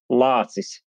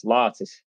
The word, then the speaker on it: Lācis: It's Lācis. L-A-C-I-S, Lācis.